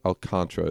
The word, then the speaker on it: Alcantara: Alcantara is how it's pronounced. – Mute that. Really? – Yeah. Just gonna skip over that whole A there.